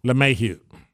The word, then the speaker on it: LeMahieu: How do you say his last name? – LeMahieu? – LeMahieu.